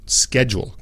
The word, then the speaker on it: schedule: Igual con schedule, ¿ok? Schedule es difícil por varias varios motivos, ¿sí? Horario, schedule, ¿sí? Schedule, schedule. No es schedule, no, schedule, schedule. Y realmente son simplemente dos sílabas.